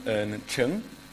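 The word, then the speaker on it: Ch'ng: My, my full name is um, Adam Ern Cheng. Okay. – Uh, I'm not quite. It's spelled uh, C H apostrophe N G, so it's like rhythm, but without the Y and no vowel substitute.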